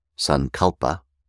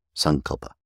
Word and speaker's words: Sankalpa: The proper way of pronouncing it in Sanskrit is on the ante-penultimate syllable, as with about 90% of words in Sanskrit, instead of the way that we do in English, which is to say Sankalpa. The proper pronunciation is Sankalpa. Sankalpa.